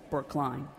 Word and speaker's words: Brookline: I will congratulate you on being one of the few places in Massachusetts that actually is pronounced like it's spelled, Brookline.